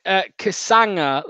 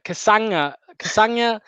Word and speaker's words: Kasanga: Ben says it's pronounced Kasanga, like lasagna. Kasanga.